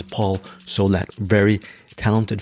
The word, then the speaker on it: Solet: I actually thought the way to pronounce his name was Paul Soleil, and I was lucky because the publicist who was handling the press for this movie, the movie is called Clean, actually pronounced his name Paul Soleil, and then he corrected her. He said it's Paul Solet.